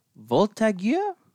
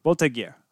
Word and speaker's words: Voltigeurs: He is an elite level player who was drafted by the QMJHL's Drummondville Voltigeurs. – Voltigeurs. – Did I get it right? – I'm pretty sure.